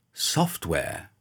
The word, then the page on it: software: British English